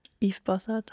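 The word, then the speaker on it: Yves Bossart: Wie würdest du den aussprechen? – Yves Bossard.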